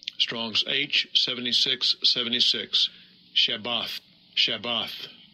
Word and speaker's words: Shabbat: And that's the word Shabbat is the Hebrew way of saying Sabbath.